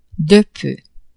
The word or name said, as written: de peu